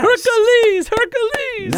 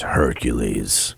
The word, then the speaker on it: Hercules: Hercules! – No, I wouldn't say it like that. – No? – No, it's Hercules.